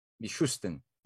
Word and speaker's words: Mishustin: It does seem that there are maneuvers being made by people like Mikhail Mishustin, I don't know if I pronounced his name right. Mishustin. Mishustin. Thank you.